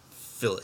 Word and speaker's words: fillet: And for you English people, that's fillet. – Fillet. Yeah. Fillet. T is silent. – Yeah. Fill it.